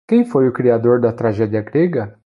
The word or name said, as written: grega